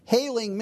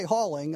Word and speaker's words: hailing: As for Saul, he made havoc of the church, and that is, he's destroying and mistreating the church, hailing men and women, committed them to prison. That you say hauling? I looked it up. It said hailing. It said it was long A in there.